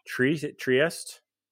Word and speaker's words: Trieste: I mean, I know there's a lot of American players, especially on the first club that you were on, Pallacanestro Trieste. Is that Trieste, how you pronounce it? – Yeah.